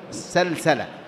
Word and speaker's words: سلسلة: قال: سلسلة، سلسلة في القاموس بهذا النطق، سلسلة بفتح أوله وسكون اللام وفتح السين الثانية منها، يعني السلسلة إيصال الشيء بالشيء، سلسله يعني خاطه بسلسلة بسلك ونحوه.